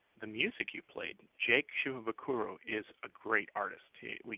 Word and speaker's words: Shimabukuro: So, but the name is Japanese, which means no stress on any of the syllables. So basically, what you just say is Shimabukuro, Shimabukuro. Same thing with Israel Kamaka Viva Oli.